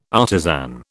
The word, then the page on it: artisan: British English